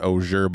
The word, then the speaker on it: Oger: But they were pronouncing it Auger, but it was like O-G-E-R.